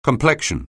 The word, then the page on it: complexion: anglais